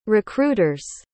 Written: recruiter's

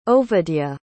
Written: Ovadya

